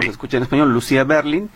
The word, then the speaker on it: Lucia Berlin: Sí, de acuerdo, soy sin acento, Lucia Berlin, sin acento.